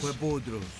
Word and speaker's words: Jueputrus: Este bombazo que acabas de escuchar se llama así y la banda impronunciable es Jueputrus. ¿La estoy pronunciando bien, muchachos? – Jueputrus. – Ajá, está bien.